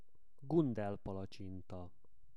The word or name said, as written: Gundel palacsinta